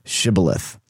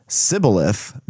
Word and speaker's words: Shibboleth: If he replied, "No," they said, "All right, say Shibboleth." If he said Sibboleth, because he could not pronounce the word correctly, they seized him and killed him at the fords of the Jordan.